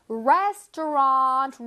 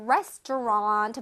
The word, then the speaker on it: restaurant: Restaurant 아닙니다. Restaurant 아니고요. Restaurant, restaurant. 끝에 랑 소리가 아니라 언 소리를 내주세요.